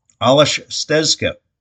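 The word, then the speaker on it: Aleš Stezka: Alashtiza Stezka. I knew I was going to butcher that. Let's try it again. Alash Stezka, also Czech goalie, by the way, 3-2 in net for the Birds this season.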